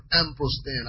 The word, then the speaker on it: emprosthen: Is the word emprostein. I'll spell that for you. E-M-P-R-O-S-T-H-E-N.